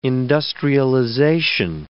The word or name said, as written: industrialization